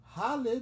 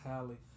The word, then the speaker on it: Halle: Halle Berry. – It's Halle. – Halle Berry. Like halitosis. Halle Berry. – It's Halle. – Halle Berry. – She's so you fine. Just gonna keep saying her name wrong? – Okay, this is the last section.